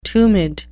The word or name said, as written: tumid